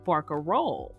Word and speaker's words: barcarolle: English speakers, largely introduced to this musical form through opera, flattened the many vowels of the Venetian word barcarola into the easier to pronounce barcarolle.